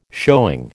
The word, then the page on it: showing: American English